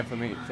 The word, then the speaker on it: Flamini: Yeah, Flamini.